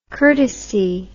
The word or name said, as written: curtesy